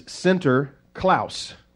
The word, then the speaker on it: Sinterklaas: And in the Dutch language, Saint Nicholas is Sinterklaas. That's how you say it in Dutch.